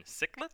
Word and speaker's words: cichlid: We've also got Kangaskhan, and I feel like that one might be the odd one out, and I'm guessing that's the African cichlid? – Yep, you're pronouncing it correctly.